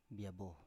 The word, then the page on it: biabo: Biak